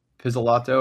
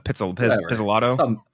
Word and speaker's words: Pizzolatto: Pizzolatto or Pazzolato, however you say his name. Definitely not Pizzolatto. Pizzolatto.